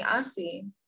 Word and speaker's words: Assi: Okay, so my whole name is Assi Atubari, and everyone calls me Assi.